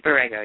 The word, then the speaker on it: Borrego: It's Borrego, yeah.